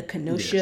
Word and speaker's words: Kenosha: The Kenosha Yes.